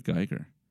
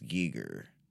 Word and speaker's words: Giger: And I might have pronounced it Geiger. – I think it's Giger.